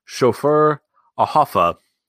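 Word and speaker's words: Schöfferhofer: So, I had to look up the pronunciation on this brewer. It's a German one. It is Schoffer Ahoffa Hefeweizen Grapefruit Beer.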